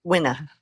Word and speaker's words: Wina: We were talking about your name, and before we came on the show, Wina is actually pronounced Wina. – Wina, which is beautiful, but you southerned it up.